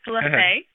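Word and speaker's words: La Fe: I want to talk to you about Lafee Consulting. Am I pronouncing that right? – Not quite, but that's okay. It's Lafee. – Lafee. – And that is Spanish for faith.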